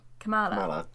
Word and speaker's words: Kamala: I'm not sure how to say her first name, so Kamala. – Kamala, yeah.